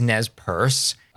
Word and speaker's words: Nez Perce: So we did pronounce it incorrectly. It is Nez Perce.